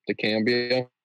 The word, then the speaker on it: dicamba: Oh, what's dicamba, or am I pronouncing that wrong? – Dicamba. It's a super strong herbicide.